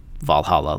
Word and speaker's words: VA-11 Hall-A: The game's title is stylized as VA-11 Hall-A, although it's pronounced Valhalla like the Viking heaven.